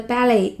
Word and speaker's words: ballet: 那么芭蕾舞剧叫做 ballet，ballet，b a l l e t，b a l l e t，那个 t 也是不发音的。好，这出芭蕾舞剧将于下个月在纽约首演。 The ballet will debut next month in New York. The ballet will debut next month in New York.